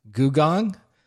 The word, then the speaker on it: Googong: I believe it's pronounced Googong.